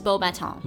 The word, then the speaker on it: Beauxbatons: Just Beauxbatons. – Beauxbatons. – You don't have to say but you don't pronounce the S.